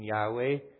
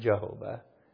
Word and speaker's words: Yahweh: It could have possibly been pronounced, those four letters, Yud Heh Vav Heh, could have been pronounced Yahovah, but it wasn't Jehovah.